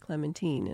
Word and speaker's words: Clementine: It's a biography of the life of Winston Churchill, and she says it's pronounced Clementine, and I've never heard that before.